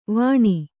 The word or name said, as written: ವಾಣಿ